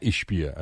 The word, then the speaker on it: Ishbia: Matt Ishbia, I believe is how you pronounce his name. Yes.